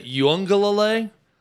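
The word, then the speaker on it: Uiagalelei: I want to say Uyunglele. I've heard it pronounced. There's like a hidden N in there with the pronunciation.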